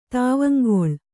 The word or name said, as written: ತಾವಂಗೊಳ್